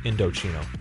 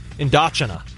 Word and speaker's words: Indochino: There's a note at the top that tells you how to pronounce Indochino. Who doesn't know? Indochina. Who would have think that? – Well, no, I had to tell you off here it was Indochino.